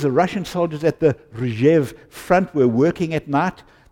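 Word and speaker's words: Rzhiv (Ржев): During World War II, the Russian soldiers, and I got Google to say the word for me because, how do you pronounce this word? Rzhiv. Rzhiv. I thought that sounded quite Russian, actually.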